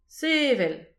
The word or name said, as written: sevel